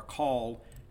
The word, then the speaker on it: Khal: And then, you know, Cal, and it's spelled K-H-A-L, but it's not Cal or Call, it's Cal.